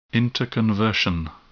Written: interconversion

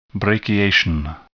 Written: brachiation

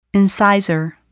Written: incisor

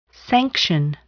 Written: sanction

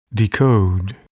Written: decode

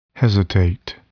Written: hesitate

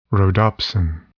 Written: rhodopsin

